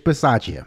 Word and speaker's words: Bisaccia: Now, of course, again, just to be clear, Rich, I think, how do you say his name? Bisaccia? – Rich Bisaccia.